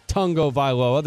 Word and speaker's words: Tagovailoa: Number one, it's pronounced Tagovailoa. There's no N, but that's how he said it's pronounced from the 972.